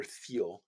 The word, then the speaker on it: Thiel: And so Peter Thiel, or Thiel, I think it's pronounced that Thiel, it's T H I E L.